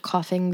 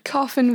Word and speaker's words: Coughin: Coughin like you're coughing. – Yeah. – You've got a cough. – Or coughing as in a wooden box. – but not spelled like that. – Yeah, but it sounds like that.